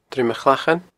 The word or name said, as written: Druim a Chlachain